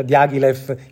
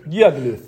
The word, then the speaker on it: Diaghilev: Solo dopo Stravinsky scopre in realtà una parte delle musiche, dei manoscritti che Diaghilev, io correggo la mia pronuncia, Diaghilev in Diaghilev, grazie al maestro Caragò. – Diaghilev.